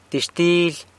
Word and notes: Dizhtily: sanlucasquiavini

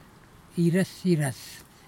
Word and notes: irɨsirɨs: kwamera

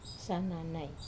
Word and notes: sananai: wamesa

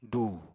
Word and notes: dùu: apatani

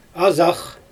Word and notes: azax: Xyzyl

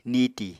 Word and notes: niti: Apatani

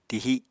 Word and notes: tihi: Apatani

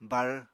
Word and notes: bar: Apatani

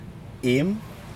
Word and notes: im: Aneityum

